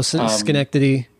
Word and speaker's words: Schenectady: Where, like, I guess my question was, like, so I'm kind of with Marin as far as, I'm going to mispronounce it, Sigodochi, New York. – Oh, Schenectady.